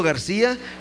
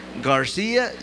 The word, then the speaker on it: Garcia: You can address it to Pastor Delfino Garcia Jr. Let me spell Delfino for you. D-E-L-F-I-N-O. That's my name, Delfino Garcia, G-A-R-C-I-A Jr. Praise God.